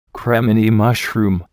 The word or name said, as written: cremini mushroom